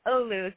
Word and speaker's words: Oglu: It's Turkish, so the pronunciation's a little tough. It's Oglu, so the G silent, so you got it right.